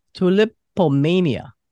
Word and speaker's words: Tulipomania: I have on my show today the Philadelphia art rock duo, Tom Murray and Cheryl Belover, Tulipomania.